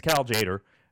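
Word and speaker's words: Cal Tjader: I think it's Cal Jader, and I think the T is silent.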